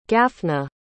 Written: gaphna

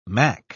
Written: mac

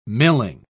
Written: milling

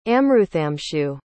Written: amruthamshu

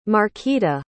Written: marquita

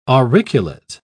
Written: auriculate

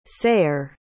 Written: sayer

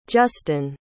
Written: justyn

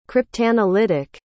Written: cryptanalytic

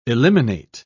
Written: eliminate

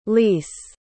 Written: leese

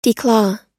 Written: declaw

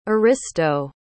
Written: aristo

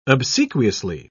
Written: obsequiously

